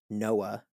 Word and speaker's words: NOAA: So National Oceanic and Atmospheric Administration, obviously NOAA is an acronym, right? But it's pronounced Noah.